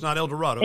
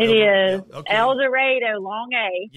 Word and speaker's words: El Dorado: It's not El Dorado. – El Dorado, long A.